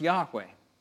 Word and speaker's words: Yahweh: Most scholars agree the name would have been pronounced Yahweh. But there were no vowels in ancient Hebrew.